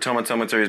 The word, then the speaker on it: Tomatometer: And I remember like I think on Rotten Tomatoes, on the Tomatometer, as has been decided by the internet as how it's we pronounce because I did a video. And everyone agrees that Tomatometer is by far the best way to talk about the Tomatometer.